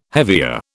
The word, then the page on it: hevea: British English